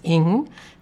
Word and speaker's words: Ng: Today, the book under discussion is Our Missing Hearts by Celeste Ng. That's spelled N-G, but that is the way it's pronounced. I checked it out.